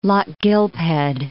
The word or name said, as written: Lochgilphead